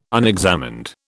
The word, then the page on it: unexamined: British English